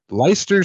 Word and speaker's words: Leicester: That's right, not London, Ontario, Canada, but London in the United Kingdom at the uh, Leicester Square Theater. L E I C E S T E R. I think you pronounce it Leicester.